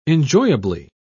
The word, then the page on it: enjoyably: British English